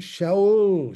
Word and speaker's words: Shaul: I know someone, and this is what his name is. Shaul, Shaul. That's how they say it.